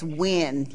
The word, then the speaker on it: Nguyen: It's Nguyen, like W-E-N, but that's how it's pronounced.